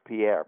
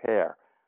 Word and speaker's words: Pierre: It's you would think it's pronounced Pierre, but it's as I learned, it's Pear.